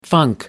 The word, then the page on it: funk: American English